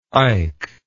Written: eyck

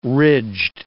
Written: ridged

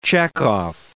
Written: Chekov

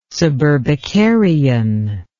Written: suburbicarian

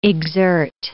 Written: exert

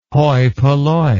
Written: hoi polloi

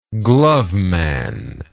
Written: gloveman

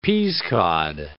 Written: peasecod

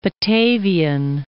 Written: Batavian

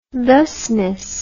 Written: thusness

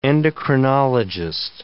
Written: endocrinologist

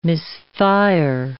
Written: misfire

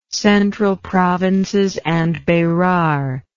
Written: Central Provinces and Berar

